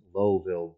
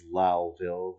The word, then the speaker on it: Lowville: And it is Lowville. I'm sure a lot of people reading this news item across the country are pronouncing it Lowville, but it is Lowville.